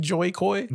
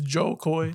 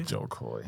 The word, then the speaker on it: Jo Koy: I always feel like saying his name wrong. I feel like always saying Joy Coy. – Joy Coy. – But it's Joe Coy. – Joe Coy.